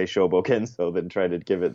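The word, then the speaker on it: Shōbōgenzō: is from his work called the Shobogenzo.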